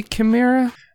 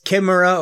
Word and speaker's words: Chimera: So, I always pronounce it Chimera OS. Is it supposed to be Chimera? – It's Chimera or Chimera. – Okay. – You can say Chimera if you want to. You can say Chimera if you wanted to. Yeah.